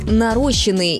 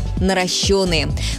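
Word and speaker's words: наращённые: Часто можно услышать «нарощенные» или «наращенные». Правильный вариант один – «наращенные». Ударение всегда падает на «ё».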